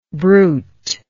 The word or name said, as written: bruiter